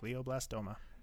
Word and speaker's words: glioblastoma: Glioblastoma? – Yep. – Glioblastoma.